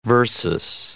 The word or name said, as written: versus